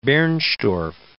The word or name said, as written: Bernstorff